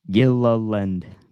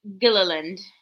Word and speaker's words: Gilliland: Kindergarten teacher got to sound off the syllables. Gilliland. – Gilliland. – Gilliland's Island. It's just like Gilligan, but land.